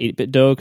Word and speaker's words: 8BitDo: which people in the chat are saying it's pronounced 8BitDo because it rhymes with Nintendo, which I guess makes some sense.